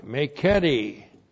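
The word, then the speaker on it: meketi (μηκέτι): And the subjunctive of zao with the negative adverb meketi. M, long E, K, E, T, I, meketi.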